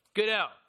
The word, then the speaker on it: Goodell: I am Roger Goodell. Goodwell, is that how you say his name? Goodwell. Goodell, there we go.